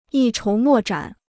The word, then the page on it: 一筹莫展: yī chóu mò zhǎn